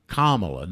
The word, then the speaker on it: Kamala: It's like a comma. Kamala, not semi-comma, but a Kamala Harris, and they took and had a whole panel on the fact that he mispronounced her name, okay?